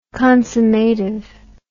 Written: consummative

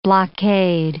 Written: blockade

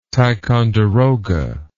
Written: ticonderoga

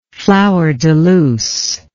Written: flowerdeluce

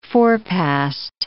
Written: forepassed

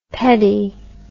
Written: pedi

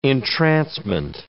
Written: entrancement

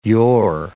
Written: yore

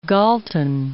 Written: Galton

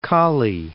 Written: Cali

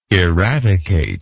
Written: eradicative

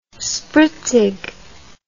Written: spritzig

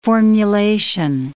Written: formulation

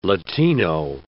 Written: Latino

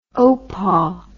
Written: oupa

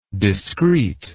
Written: discreetness

